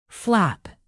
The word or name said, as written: flap